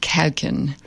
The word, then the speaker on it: Kuijken: It's spelled K-U-I-J-K-E-N, and it's pronounced Kauken.